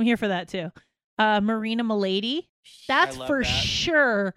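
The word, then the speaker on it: Melady: Straight up pronounced Milady.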